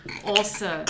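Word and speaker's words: Åse: My Norwegian pronunciation is not good, but it appears this woman's name is Ass Cleveland. – also. It's A-S-E. It's also.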